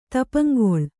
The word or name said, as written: ತಪಂಗೊಳ್